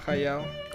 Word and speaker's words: Hayao Miyazaki: A ver, Hayao Miyazaki, ¿sí se pronuncia así? – Ajá, Hayao.